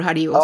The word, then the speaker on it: Haribo: I have a story too that relates to the intake of Haribos. Is that how you pronounce it? – Haribo.